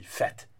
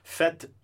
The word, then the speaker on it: fête: So for example, I'll give you a common one would be everyone's favorite word would be fête. Une fête. Fête, which is spelled F-E, this accent circonflex, T-E, well, it just means party, right?